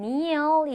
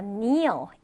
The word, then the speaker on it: kneel: 자, 아래 동사 형태를 보면 kneel 있어요. kneel. 자, kneel. 이건 무릎을 꿇다라는 동사가 되죠. 이때도 마찬가지로 K는요, 네, 발음을 하지 않고 그냥 kneel이라고 읽어주시면 되겠습니다.